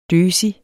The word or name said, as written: døsig